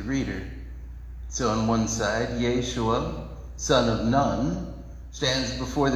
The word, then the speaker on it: Yeshua: in the original language, both of their names, Joshua and Jesus, both of their names are pronounced Yeshua.